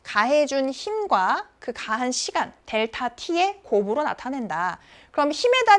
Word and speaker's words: 델타: 근데 이 앞에 있는 이 삼각형은 무엇이냐? 얘는 델타라고 읽습니다. 델타.